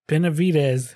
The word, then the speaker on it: Benavidez: We're going to talk about one of the most badass dudes in recent history, Roy Benavidez. I bet I'll like mispronounce it 5 times tonight. – Benavidez. – What did I say? Benavidez? – Benavidez. – Oh, embarrassing.